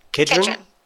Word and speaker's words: Kedron: Kedron.